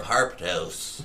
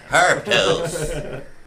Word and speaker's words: Harptos: Kythorn is the sixth month of the harp calendar of Harptos. – Is that how you have to say that? – Yeah. – That's how pronounced. Okay. – Harptos.